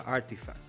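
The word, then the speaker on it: RTFKT: A los glitcheros que no escuchan pueden buscar Artifact, eh, eh, eh, que, se escribe RT, RTFK, Artifact.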